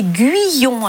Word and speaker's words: Guyon: Et le nom d'origine, c'était Guyon. Alors, je le prononce comme ça, parce que ça s'écrivait au départ G-U-Y-O-N. Au Québec, en 7 générations, c'est devenu Guyon, oui. – G-U-I-O-N, et Guyon, Dion, voilà, à l'oreille.